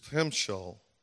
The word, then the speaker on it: timshel: This word in Hebrew is timshel, T-I-M-S-H-E-L, but it's pronounced timshel.